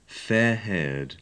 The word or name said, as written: fair-haired